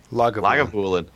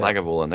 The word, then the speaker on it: Lagavulin: And he pulled out the Langevulen. Is that how you say it? – Lagavulin. – Yeah. Lagavulin.